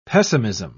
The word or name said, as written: pessimism